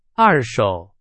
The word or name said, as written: 二手